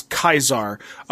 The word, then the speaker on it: Caesar: Members of the Legion pronounce Caesar's name as Kaisar, a post-classical Latin pronunciation emphasizing the diphthong? D-I-P-H-T-H-O-N-G.